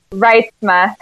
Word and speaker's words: Reitsma: Reitsma.